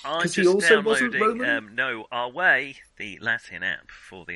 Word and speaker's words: Ave: Because Our Way was the Latin greeting. Even though it looks like Ave, they pronounce it.